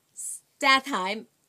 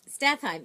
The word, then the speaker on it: Statham: Homefront was written by Sylvester Stallone, based on a novel by Chuck Hogan, and it stars Jason Statham. I got his name right. – Statham. – S-T-A-T-H-A-M.